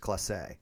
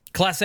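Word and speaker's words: Clase: I believe, according to Baseball Reference, it's pronounced Classe. – Classe. I'm not editing it out. I'll just say it right from now on.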